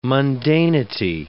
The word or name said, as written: mundanity